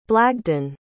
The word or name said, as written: blagdan